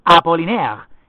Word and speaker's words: Apollinaire: Se pronuncia, que en francés se pronuncia Apollinaire.